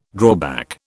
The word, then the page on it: drawback: British English